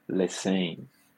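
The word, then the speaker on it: Lesesne: Semana passada você falou um pouquinho da, da de como que estava o Troy Lesser, uh, desculpa a pronúncia em francês, perdão? Lessen.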